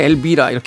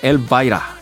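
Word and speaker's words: Elvira: 1981년도 빌보드 핫백 차트 이번 주 5위에 올라있던 The 오크리지 보이즈의 엘바이라 네, 우리나라 표기법으로 엘비라 이렇게 하는데 노래 부를 때 들어보니까 엘바이라 이렇게 발음을 합니다.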